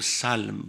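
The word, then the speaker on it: سَلْم: Selm or Salm, both of them have been pronounced in the Quran.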